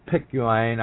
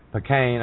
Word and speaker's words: Picayune: Tyler Bridges, who was then an investigative reporter for the New Orleans Times, Picayune, I can't pronounce that, it's P-I-C-A-U-N-E, that's some sort of Cajun or Creole terminology, Picayune, I think it's the way it's pronounced, the New Orleans Times Picayune newspaper, revealed in 1989 that Duke secretly owned two companies.